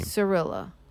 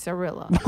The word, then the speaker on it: Cirilla: Cirilla. – Oh. Hmm? – Cirilla. – It's got a fucking C in it. – Yeah, C that's pronounced like an S in this case. Cirilla.